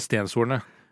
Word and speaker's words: Stenshorne: på å finne ut av hvordan uttaler vi etternavnet. – Og fasit er? – Eh, Stenshornet.